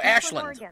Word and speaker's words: Ashland: No, Ashland. It's L-A-N-D, but they pronounce it Lind.